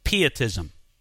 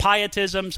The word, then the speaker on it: Pietism: Therefore, there rose up a counter movement, so to speak, within Lutheranism known as Pietism. Some people pronounce it Pietism, some people pronounce it Pietism. It's spelled P-I-E-T-I-S-M. Pietism or Pietism, whichever one you choose.